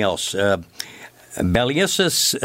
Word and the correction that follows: Bacillus thuringiensis: No. No, bacillus. – B- bacillus thuringiensis. – Perfect.